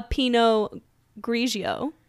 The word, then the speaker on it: Pinot Grigio: And so I am drinking a Pinot Grigio. – Yes, you said that right.